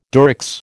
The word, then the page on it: Durex: American English